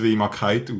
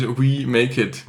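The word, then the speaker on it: wemakeit: Also das habe ich schon das erste Mal, wenn ich es gesehen habe, ich so ausgesprochen. Habe ich wirklich gesagt, was soll das heißen, Wemakite? Hä? – Ja. – Und dann ist irgendwann der Grosch gefallen. – Ja, so. Dann ist We Make It.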